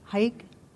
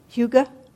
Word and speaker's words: hygge: In Denmark, which is one of the happiest countries in the world, there is a word, H-Y-G-G-E. What is it? How do you pronounce it? Hike? Hedge? Hygge? Hygge. I knew I'd say it wrong.